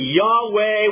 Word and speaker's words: Yahweh: We think the best pronunciation for this would be Yahweh, what is sometimes called the Tetragrammaton.